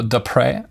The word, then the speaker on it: Depré: So the next question is by Michael Depré. It's a French name. Sorry again for the last name. He says it's pronounced Depré. So Michael Depré.